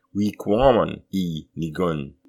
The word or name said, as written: Wiqômun i nikun!